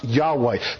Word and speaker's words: Yahweh: which is pronounced Yahweh, the covenantal name of Creator God.